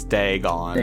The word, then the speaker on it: Dagon: Oh, it's actually Dagon. – It's Dagon. Dagon. But, like the dragon? – Yeah, it's like dragon without an R.